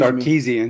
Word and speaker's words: Sarkisian: Before we get there, let me show you that Steve Sarkisian, if I'm saying his name correctly, excuse Sarkisian.